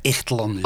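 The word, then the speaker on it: Ixtlán: Ja, volgens mij spreek je in het Spaans de X als, uit als een G, hè? dus Ixtlan.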